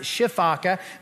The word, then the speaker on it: sifaka: A sifaka, or some people pronounce it shifaka.